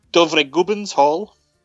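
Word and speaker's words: Dovregubbens hall: And it's in Norwegian, it's Dovre Gubben's Hall. Yeah, I pronounced perfectly.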